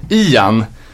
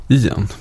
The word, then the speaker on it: Ian: Det är inte Ajan som är aj, det är ont. Utan det är i, Ian, som i eh, samma uttal som på den här ledsna åsnan Ior.